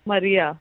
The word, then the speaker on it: Maria: Is it Maria or Marie? – It's a noun, so I think you can spell it however you want. But it's Maria.